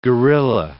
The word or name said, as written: guerilla